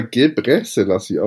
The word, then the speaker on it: Gebrselassie: ja, also ich glaube nicht ganz richtig, weil man spricht das immer Gebre Selassie aus.